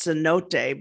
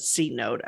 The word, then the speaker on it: cenote: I think it's pronounced cenote, but I said C note at the time.